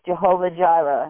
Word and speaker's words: Jehovah Jireh: The name revealed there was Jehovah Jireh, as we pronounce it in English.